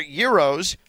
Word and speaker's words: gyros: So I think that I'm ready to declare that Euros are an elite food. Are Euros an elite food? And do not call them gyros because that is not how you pronounce it.